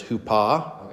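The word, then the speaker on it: hupa (ὑπό): So then pronunciation, you're real close, but it's hupa, okay.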